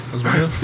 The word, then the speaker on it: Los Baños: No, I think you said it right. Los Baños. Yeah, Los Baños.